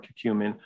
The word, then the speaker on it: curcumin: curcumin.